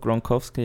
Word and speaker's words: Gronkowski: so mit dem Gronkowski, Jetzt habe ich den Namen wahrscheinlich völlig falsch ausgesprochen. Doch, Gronkowski, ja.